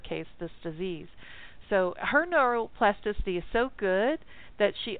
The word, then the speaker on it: neuroplasticity: neuroplasticity is how it's pronounced.